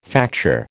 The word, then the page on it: facture: American English